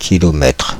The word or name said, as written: kilomètre